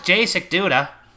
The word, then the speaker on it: Jacek Duda: But he pronounced Jacek Duda. It was a silent J. That was Jacek Duda.